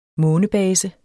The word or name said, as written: månebase